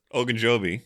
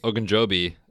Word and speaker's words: Ogunjobi: Ogunjobi. – Ogunjobi.